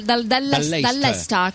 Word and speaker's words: Leicester: Dal Leicester. Chi ce lo racconta? – Leicester.